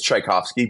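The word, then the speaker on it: Czajkowski: His last name is Tchaikovsky, but spelt with almost every letter different. If you look at how he spells it, it's like CZ versus instead of THC, it's CZ. But it's pronounced the same.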